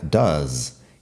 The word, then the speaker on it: does: Does se escribe D-O-E-S y fíjate que la pronunciación también cambia. I do, you do, he does, she does, it does, we do, they do. Estas son las conjugaciones ahí y fíjate que does no suena mucho al do, la vocal es diferente.